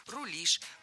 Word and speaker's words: рулишь: Кроме того, ударение в нашем слове тоже стоит всегда за корнем, как и в начальной форме. «Рулить», «рулит», «рулим», «рулишь».